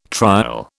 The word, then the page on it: trial: British English